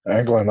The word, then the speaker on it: England: Correct me if I'm saying that wrong. – England like the country.